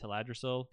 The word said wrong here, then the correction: Teldrassil: Teldrassil. – Teldrassil.